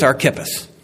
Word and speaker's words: Archippus: I wanted to say Archippus, but according to the app, it's Archippus. I'll go with that.